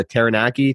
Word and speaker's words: Taranaki: No, so I just saw on your Instagram that you're at a fairly local triathlon race to you in, now correct me if I'm saying this wrong, but New Plymouth, New Zealand, called the Taranaki Tri Festival. – Oh, perfect. Perfect.